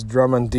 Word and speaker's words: Drummond: I don't know how to say the name, but it's Drummond, D-R-U-M-M-O-N-D.